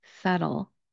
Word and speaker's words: subtle: The seventh word on our list is very subtle. The word is spelled S-U-B-T-L-E. If you look at it, it should be something like subtile. Again, when we look at the spelling, we're going to take out a letter and we're not going to pronounce it.